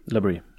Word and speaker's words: La Brea: Dette er et serietips, eh, og jeg er litt usikker på hvordan dette uttales, men Labrie, eller la L-A-B-R-E-A. Labrie. Ja, Labrie.